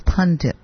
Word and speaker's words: pundit: In a discussion about politicians and celebrities who run to rehab in times of crisis, this was on Fox, naturally, conservative pundit... Don't put that N in there in the second syllable. It's pundit.